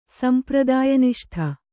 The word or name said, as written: ಸಂಪ್ರದಾಯನಿಷ್ಠೆ